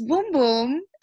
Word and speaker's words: Bum Bum: It's spelled B-U-M-B-U-M. So we naturally, as you know, Americans, the way we like to jack things up, we're like bum bum, but Camila was like, no, it's boom boom.